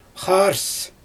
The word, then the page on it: xaars: Xyzyl